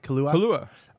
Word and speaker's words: Kaluuya: Kaluuya.